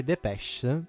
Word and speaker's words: Depeche: Che peraltro a me fa ridere perché Depeche mi sembra quando vado giù al sud che ti chiedo il pesce. – Depeche. Però l'hai detto bene, quindi va Depeche.